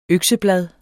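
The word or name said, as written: økseblad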